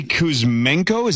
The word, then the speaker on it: Kuzmenko: Yeah, so Andre Kuzmenko, is that how you say it, Zachary? – Yes.